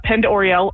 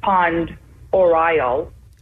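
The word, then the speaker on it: Pend Oreille: I think the most typical pronunciation is Pond Oriel.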